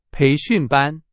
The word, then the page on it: 培训班: Chinese